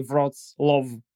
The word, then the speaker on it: Wrocław: So it's Wrocław. But you can say Wrocław.